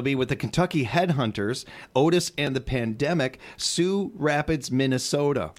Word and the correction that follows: Sauk Rapids: Sauk Rapids, by the way. – Oh, yes. Thank you. I'm sorry. I knew I was going to say that wrong.